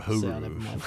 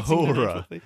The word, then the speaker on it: Uhura: Did I call it Uhuru? – You called her Uhuru. – So, sorry. – Rhymes with the Yazoo. Yoohoo glue.